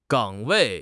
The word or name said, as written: 岗位